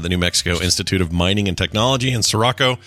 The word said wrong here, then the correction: Socorro: Socorro, rather, I think is how you say it, is taking birds that have been preserved through taxidermy and converting them into drones in order to study flight.